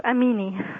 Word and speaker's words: Amini: Yes, Amini. – Amini. A-M-I-N-I.